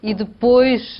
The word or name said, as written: e depois